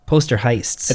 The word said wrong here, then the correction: poltergeist: How do you pronounce poltergeist? – Poltergeist.